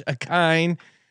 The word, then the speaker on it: Ikhine: IKHINE Westbrook - Akine.